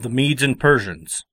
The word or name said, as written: Medes and Persians